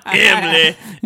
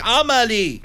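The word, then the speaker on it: Amélie: It's not Emily. Emily. – It's Amelie. Amelie.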